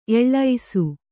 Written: ಎಳಸು

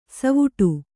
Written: ಸವ್ವಡಿ

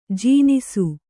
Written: ಜನಿಸು

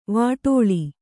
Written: ವಿಟಾಳ

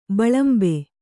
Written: ಬಿಲಿಂಬಿ

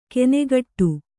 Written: ಕೆನೆಗಟ್ಟು

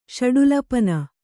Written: ಷಡುಲಪನ

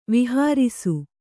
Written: ವಿಹಾರಿಸು

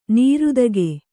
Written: ನೀರ್ತೆಗೆ

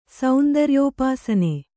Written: ಸೌಂದರ್ಯೋಪಾಸನೆ

